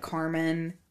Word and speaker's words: Carmon: It's by Colette Carmon. And that is spelled C-A-R-M-O-N. – Yeah, C-A-R-M-O-N, because it's a family name, actually.